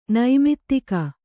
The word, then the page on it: ನೈಮಿತ್ತಿಕ: kannada; naimittika